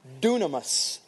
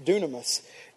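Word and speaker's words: dunamis (δύναμις): This power is dunamis, is how you pronounce it, dunamis.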